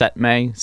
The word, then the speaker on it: CETME: It's pronounced Setme, C-E-T-M-E.